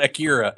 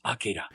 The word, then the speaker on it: Akira: It's also not Akira. It's Akira.